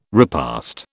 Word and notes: repast: British English

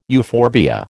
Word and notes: euphorbia: American English

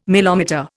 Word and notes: milometer: Indian

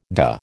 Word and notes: dah: American English

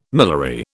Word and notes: miliary: British English

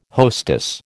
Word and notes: hostess: American English

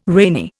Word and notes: rainy: Indian

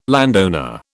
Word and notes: landowner: British English

